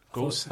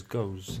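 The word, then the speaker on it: Gose: Before you start, is it actually pronounced gosa? – It's like gosa? Gosa? – I thought it was goes. – Nah, so the E is not. The goose is the gers one. The one that's spelled like gooey-wee. That's gers. – The Belgian one, right? – Yes. – Yeah. – Gosa is a German like fruit beer.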